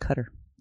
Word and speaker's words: Qatar: It's pronounced Cutter.